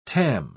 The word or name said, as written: tam